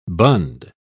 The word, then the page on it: bund: British English